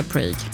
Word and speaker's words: Prague: Tydligen så uttalas Prag likadant på engelska som på svenska typ. Svårt. Prag. Han vill säga Prague.